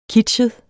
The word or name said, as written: kitschet